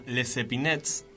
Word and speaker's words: Les Epinettes: El tema se llama Los Spinettes. – O casi. El tema se llama Les Epinettes, que contrariamente a lo que uno podría pensar a primera vista, no, se escribe Les Epinettes, así que la similitud es profusa.